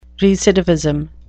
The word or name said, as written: recidivism